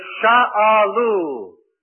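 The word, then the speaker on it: sha'alu (שאלו): The word for prayer is sha'alu.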